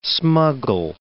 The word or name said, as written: smuggle